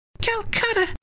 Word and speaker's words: Calcutta: Calcutta? I like the sound of that. It rhymes with butter.